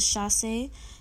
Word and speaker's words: chassé: And then another French vocabulary term for ballet is chassé. It's spelled C-H-A-S-S. I believe the accent is on the E.